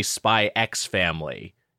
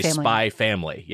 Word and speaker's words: Spy X Family: By the way, I think I said this on the podcast last time, but I've been told that you don't say Spy X Family. You say spy family. Yeah, it's like hunter. It's the same sort of thing. The X is unpronounced,